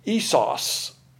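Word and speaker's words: Esos (ἴσος): The word is I-S-O-S, which is pronounced Esos.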